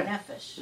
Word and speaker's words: nefesh: Nafesh. – Na, the emphasis on the M-E. – Ne. – Nefesh. – Nefesh. All right, nefesh.